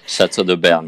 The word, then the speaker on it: Chateau de Berne: Now you're also the brand ambassador for Chateau de Bern. – Yes. – Did I say that right? – Yes. – Okay, I'm getting better with my French. I'm working on it. – Chateau de Berne, yes.